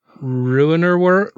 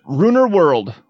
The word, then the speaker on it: Ruinerwold: The family were found after the eldest of the children ordered beer at a bar in the nearby village of Runnewold? – Ruinerwold? – Ruinerwold. We're going to call it Ruinerwold.